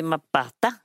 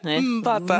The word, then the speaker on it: Mbata: Ja, nu har jag bjudit in Joy Mapata. – Yeah. Var det rätt? – Mbata.